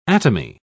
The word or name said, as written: atomy